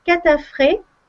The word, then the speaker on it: Katrafay: C'est la synergie catafré. Alors, le catafré, ça s'écrit K-A-T-R-A-F-A-Y, ça s'écrit Catrafé, e, mais en fait ça se lit Catafré e, parce que c'est plus ah facile à prononcer.